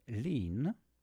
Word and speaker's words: Linh: So talking about the first people who are number one during 2025, there's a song from Lynn, so it's spelled L-I-N-H,